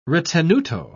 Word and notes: ritenuto: British English

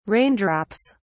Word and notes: raindrops: British English

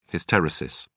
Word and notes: hysteresis: British English

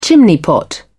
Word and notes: chimney-pot: British English

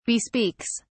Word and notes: bespeaks: British English

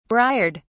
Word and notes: briard: British English

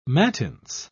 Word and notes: mattins: British English

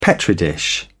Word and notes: petri dish: British English

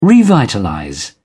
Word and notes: revitalize: British English

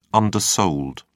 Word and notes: undersold: British English

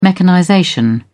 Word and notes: mechanization: British English

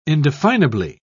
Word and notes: indefinably: British English

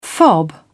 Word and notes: fob: British English

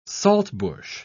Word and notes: saltbush: British English

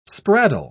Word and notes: spraddle: British English

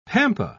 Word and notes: pampa: British English

